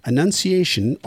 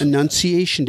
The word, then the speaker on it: Annunciation: Annunciation Day. A-N-N-U-N-C-I-A-T-I-O-N. Yeah, but the reason that we were giving you a heck is because you pronounce it exactly the same as enunciation.